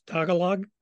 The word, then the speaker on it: Tagalog: I'm presuming I'm going to mispronounce this. Tagalog, or Dialogue, yes.